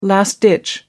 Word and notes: last-ditch: American English

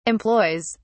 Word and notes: employs: British English

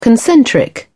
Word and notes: concentric: British English

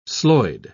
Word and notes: sloyd: British English

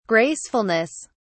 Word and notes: gracefulness: British English

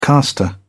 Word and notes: caster: British English

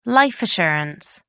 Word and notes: life assurance: American English

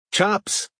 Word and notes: chops: American English

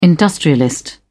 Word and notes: industrialist: British English

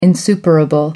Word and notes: insuperable: American English

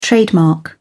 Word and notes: trademark: British English

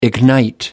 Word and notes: ignite: American English

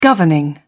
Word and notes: governing: British English

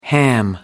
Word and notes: ham it up: American English

